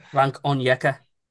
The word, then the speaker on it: Frank Onyeka: Frank Onyeka.